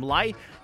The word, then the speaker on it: LI3: The opening track was by the band called I forced everyone to agree to call them Lie, but it's spelled like L-I-3.